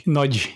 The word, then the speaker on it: Nagy: Nagy is pronounced Imre Nagy. – Is it Nagy? – Nagy. – I did not know that. – It's trying to pronounce G and Y together, like G.